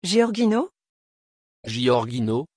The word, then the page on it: Giorgino: French